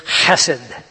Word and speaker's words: chesed: The word is chesed, and it's spelled C-H-E-S-E-D. You have to say it as a guttural, which is very difficult. The Hebrews are very good at gutturals.